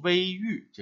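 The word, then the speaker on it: 隈隩: 那么这个地方，因为它在山沟里遇到了一个山洞啊，曲曲折折的走进去，所以用“ 微玉” 这个读音要更好一点，叫“ 山口前行始微玉”，意思就是在山上碰见了一个洞口啊，悄悄的走了进去，一开始。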